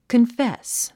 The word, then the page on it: confess: kənfés; カンフェス